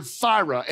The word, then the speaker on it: thyra: And as a matter of fact, the original Greek word is the word thyra, and it's spelled T-H-Y-R-A.